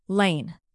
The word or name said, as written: lane